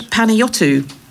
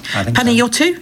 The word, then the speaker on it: Panayiotou: Yorgos Kyriakos Panayiotou. I think that's how you say it. Panayiotou, yes.